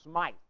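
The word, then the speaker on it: Smyth: But, as I said, John Wesley, the founder of Methodism, about 1739, John Smith, some pronounce his name John Smythe, the founder of the Baptist Church, about 1607.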